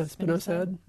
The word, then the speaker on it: spinosad: Yeah, spinosad.